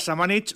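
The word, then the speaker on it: Šamanić: Lucas Samanic, lo pronunciamos bien así, según nos ha confirmado el propio jugador en la sala de prensa del Buesa Arena.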